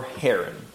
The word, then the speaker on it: Haran: That's or Haran. Okay. It's just another pronunciation.